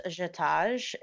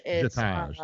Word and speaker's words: JETOJ: Another thing that I saw your involvement with, and I'm not sure I'm going to say this right, is a jet oj. – J-E-T-O-J. – How do you say that? – Jetage. – Jetage, thank you.